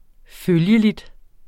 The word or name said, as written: følgelig